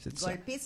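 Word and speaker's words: Golpe: Il y a une chanson qui s'appelle, là, peut-être que je le prononce mal, Golp. – Golpé, c'est notre première.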